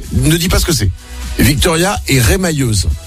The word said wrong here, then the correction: remailleuse: C'est ce que c'est? – Rémailleuse. – C'est ça, avec les mailles. – Remailleuse. – On dit, ça se prononce pas comme ça? Remailleuse? – Remailleuse, oui. – Ah, remailleuse, pardon.